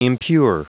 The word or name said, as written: impure